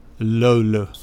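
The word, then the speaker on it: löyly: Which I will pronounce at some point, but I'll let you very capably pronounce that. – Lolo. – Lolo. – Yeah. Oh, very close.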